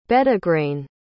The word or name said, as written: bedegrayne